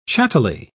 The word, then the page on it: chattily: British English